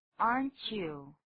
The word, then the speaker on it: aren't you: 一般这个缩写型 aren't 这个字还不好念呢，怎么念的呢？aren't 不过跟 you 一起念的时候有一点音变，要念作 aren't you， aren't you 本来是 aren't you， aren't you t 跟 y 一起念的时候常会变成 ch 的音。